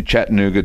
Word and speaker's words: Chattanooga: Chattanooga, Tennessee, I said that right, Peterborough, Ontario, Canada, West Newbury, Massachusetts, and Falls Church, Virginia.